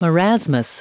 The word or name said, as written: marasmus